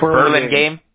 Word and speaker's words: Burlingame: Burlingame.